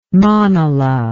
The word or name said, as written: manala